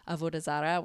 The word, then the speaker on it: Avodah Zarah: We did talk about the concept of how it gets pronounced in the Jewish community is Avodah Zarah,